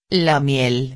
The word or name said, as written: la miel